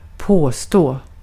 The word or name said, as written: assert